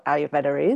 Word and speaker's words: Ayurveda: I'll just start with a little sort of overview of what Ayurveda is. It's an awkward one to pronounce in English. It's got that little R in the middle there that you kind of don't really hear.